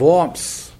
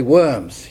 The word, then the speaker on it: Worms: It's spelt in our English language W-O-R-M-S. We would, without an education, probably say Worms. You ever been to Worms? But the Germans say Worms. Can we all say Worms? Worms.